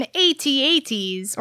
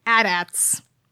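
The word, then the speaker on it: AT-AT: It's like it's built on freaking AT-ATs, or I'm sorry, AT-ATs. – 40 of them. – That's how they're actually pronounced.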